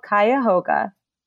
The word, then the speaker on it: Cuyahoga: It's called Cuyahoga. I'm going to spell it for you now. Cuyahoga, Cuyahoga, which is the name of the river that separates Cleveland and Ohio City.